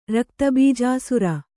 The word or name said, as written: ರಕ್ತಬೀಜಾಸುರ